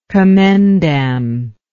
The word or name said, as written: commendam